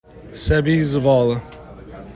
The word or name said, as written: Seby Zavala